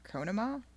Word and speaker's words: Conemaugh: I think it's pronounced Conemaugh, C-O-N-E-M-A-U-G-H, one of those good Pennsylvania names.